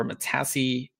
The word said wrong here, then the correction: Matisse: is that how you say it? – Matisse.